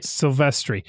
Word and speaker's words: Silvestri: Alan Silvestri is did I say it right that time? Silvestri? – There you go. Now you said it right. – Silvestri.